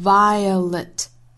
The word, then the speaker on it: violet: violet 这个词虽然是很短的一个词，但是它包含三个音节，分别是 vi 和 a，还有最后的 let，这个词呢读成 violet violet。